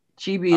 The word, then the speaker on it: chibi: Chibi? Yeah. Is that pronounced right? – Yeah. Chibi All is right.